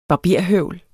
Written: barberhøvl

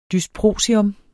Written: dysprosium

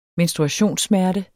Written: menstruationssmerte

